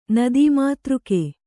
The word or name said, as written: ನದೀಮಾತೃಕ